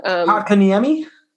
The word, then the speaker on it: Kotkaniemi: Kotkaniemi? – Yes.